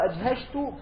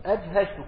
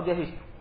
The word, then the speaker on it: أجهشت: أما قول أجهشت فهو بالجيم والشين المعجمة والهمزة والهاء مفتوحتان، أجهشت كما نطق هكذا وقع في الأصول التي رأيناها.